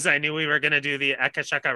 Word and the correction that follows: Akashic: Records with Jen. Sorry, did I say that wrong? – No, you said it wrong. – Akashic, that's pretty close.